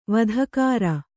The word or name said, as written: ವಧಕಾರ